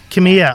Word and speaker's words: Kamiya: Gary Tamaya's book, Cool Gray City Kamiya. – Of Love. – Yeah. – Kamiya. I'm sorry, Gary.